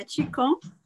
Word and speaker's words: Qigong: Yeah, Qigong.